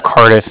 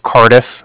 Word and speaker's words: Cardiff: And I was calling with the Bank of Cardiff. Cardiff is spelled C-A-R-D-I-F-F, as in Frank, so it's like car and then different. Mm-hmm.